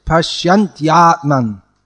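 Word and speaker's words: paśyanty ātmany: But the y has to come. It's not that it's dropped. Yeah, pronounce it pashyantyatman.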